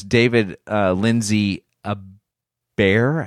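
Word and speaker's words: Lindsay-Abaire: So the writer on this is David Lindsay Abair, I think is how you pronounce his last name.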